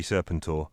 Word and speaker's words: Serpentor: Because it's got the word serpent in it. And so it should be Serpentor, not Serpentor.